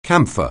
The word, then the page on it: camphor: anglais